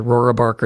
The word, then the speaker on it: Rohrabacher: This is a district Republican, Dana Rohrabacher, defeated Bacher, rather, Rohrabacher. It's hard to say his name, R-O-H-R-A-B-A-C-H-E-R, Rohrabacher, defeated his opponent by nearly 17 points in 2016.